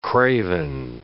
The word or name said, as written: craven